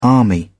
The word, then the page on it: army: British English